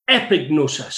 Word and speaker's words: epignosis: And the Greek word for that, and we're finished with Greek after these two words, is epignosis. So it's E, it's epic, but it's spelled E-P-I-G-N-O-S-I-S. O-S-I-S, epignosis.